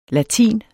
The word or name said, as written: latin